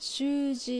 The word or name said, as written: Shuuji